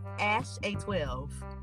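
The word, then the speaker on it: X Æ A-12: So, the way that you pronounce the XAEA-12 is Ash-A-12.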